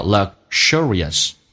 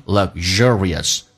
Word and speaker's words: luxurious: Alright，英式发音叫 luxurious，美式发音 luxurious，发成 z 这个发音，而且重音就在 z 这个上面。